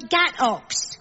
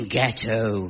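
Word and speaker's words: gateaux: Oh, you mean ghetto, ghetto. It may look as if it should be pronounced ox, but in fact, one says oh.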